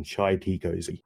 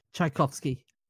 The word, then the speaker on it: Tchaikovsky: I can never say his name properly anymore because I always drive my girlfriend insane by calling him Chai Tea Cozy. – Pyotr Ilyich Tchaikovsky.